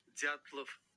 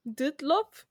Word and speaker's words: Dyatlov: Hold on, let me search up the pronunciation. Let's see what it says. – Dyatlov. – What? Dutlov? – Dyatlov. – Dutlov? Is it Russian? Yeah, it's Russian.